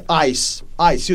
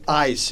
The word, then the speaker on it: AIS: ICE. You could call it ICE if you want, which is how we pronounce it in Italian.